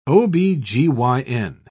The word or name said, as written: ob-gyn